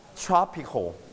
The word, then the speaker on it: tropical: In the hot area, we call tropical. Repeat after me. Listen tropical. – To the audio. Two times. – Tropical. – Your turn, tropical.You stress on the first syllable, tropical.